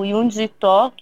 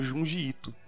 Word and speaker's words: Junji Ito: Eu sempre ouço como Junji Ito. – Porra, não, é. é Yun, eu acho que é Junji Ito.